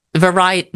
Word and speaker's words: Verity: Variety. I don't know. – Yeah, me. – It's Verity.